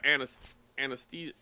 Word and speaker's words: anesthesia: But and then, you know, and I've been under amnesia or anesthesia, right? Am I saying that right? – Yep.